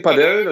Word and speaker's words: Padel: Mm, in Spanien Padel, das hast du gerade schon richtig ausgesprochen.